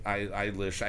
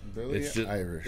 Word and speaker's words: Eilish: Eilish. I can never say her name without saying Irish. It's just... – Billie Irish.